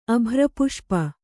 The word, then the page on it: ಅಭ್ರಪುಷ್ಪ: abhrapuṣpa